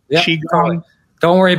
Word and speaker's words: Qigong: So anyway, however you spell it in English, it's pronounced Qigong.